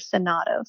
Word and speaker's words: Sagato: And a friend of mine named Ashley, who's Samoan, told me once that this is a Samoan word and that they would pronounce it Sonato. So that's how I always say it. But I wanted to point out for anyone who goes looking for it that it actually has a G.